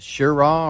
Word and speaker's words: Cheraw: Looks like it's just short of a record crest at, is that Shiraw or Cheraw? How do you say that? – Shiraw. – Okay.